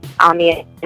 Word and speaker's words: Amiyelle: You can definitely find me on Instagram at Amiel, A-M-I-Y-E-L-L-E, exactly how it's spelled.